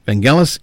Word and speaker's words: Vangelis: No, Vangelis is the proper pronunciation. So, like I said, so this is so I don't get flooded with emails saying, oh, you're pronouncing it wrong, you're pronouncing it wrong. No, no, I have it on very, very good authority that it is pronounced Vangelis.